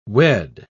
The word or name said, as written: wed